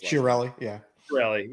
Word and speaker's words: Chiarelli: There was even one mention of Chiarelli. I don't know how you pronounce this. – Chiarelli.